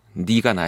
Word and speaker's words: 네가: 항상 이 내와 내의 발음이 항상 이 라디오 하다 보면은 어렵습니다. 이 DJ 하는 사람들한테는 가장 힘든 노래 제목이네요. 정준영의 내가 너에게 가든, 전 네가로 읽을게요. 네가 나에게 오든.